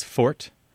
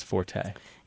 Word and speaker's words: forte: And I think you'll find that most very careful speakers will pronounce it as fort. And then, but most people in general pronounce it as forte. – Yeah, the vast majority, I think, say forte. – Yeah, most, I mean, we're talking like 99%. And actually, many people, if they hear it pronounced fort, are confused because they don't know what you mean.